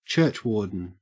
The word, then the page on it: churchwarden: Australian English